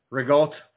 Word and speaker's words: ricotta: Rigote, for all you know, people who want to say it correctly, it's pronounced rigote.